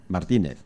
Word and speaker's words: Martínez: Martínez, con acento en la I. – Correcto.